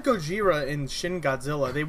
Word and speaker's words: Gojira: In the like OG movie, you see it spelled and pronounced Gojira with like a J sound.